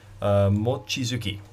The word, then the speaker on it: Mochizuki: Starting at the top in A1, Mochizuki.